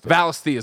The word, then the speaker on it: Valisthea: Is that how they pronounce it in the game? You play the game. – Yeah. Everyone says it's Valisthea is what I'm saying.